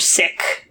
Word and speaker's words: Sikh: And we English speakers tend to pronounce it Sikh, I think because we're shying away from, we don't want it to sound like the English word sick. – Mm-hmm. – But I believe in its own language, Sikh is the correct pronunciation, Sikhism.